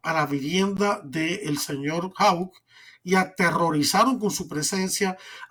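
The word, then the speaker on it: Houck: Mark Hauck, se pronuncia hauck padre de siete hijos y pacífico consejero provida frente a centros abortivos.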